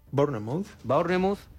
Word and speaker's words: Bournemouth: Bournemouth.